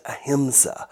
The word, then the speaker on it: ahimsa: It's pronounced ahimsa.